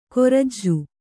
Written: ಕೊರ್ಜು

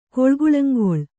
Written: ಕೊಳ್ಗುಳಂಗೊಳ್

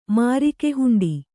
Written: ಮಾರಿಕೆಹುಂಡಿ

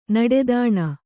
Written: ನೀಟುತನ

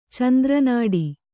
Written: ಚಂದ್ರನಾಡಿ

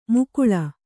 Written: ಮುಕುಳ

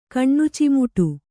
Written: ಕಣ್ಣುಚಿಮುಟು

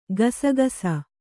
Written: ಕಸಕಸೆ

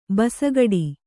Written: ಭುಸುಗುಡು

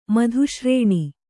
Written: ಮಧುಶ್ರೇಣಿ

